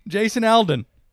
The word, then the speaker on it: Jason Aldean: I was just stumbling through, and have you heard of this guy, Jason Aldean? Did I say his name right? – I think so. – Aldean? – Jason Aldean.